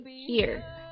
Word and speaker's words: hear: 好了，一起来看一下今天词汇扩展，看一下 hear 这个单词， hear H-E-A-R hear 跟我们的那个这里的单词是同样的读音，它表示听，听到。